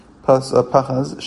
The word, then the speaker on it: Pachas: Pachas, Shem Davar, it's a noun. Pachas, haste. That's why the emphasis is on the first. Is on the second to last syllable, which is the pay.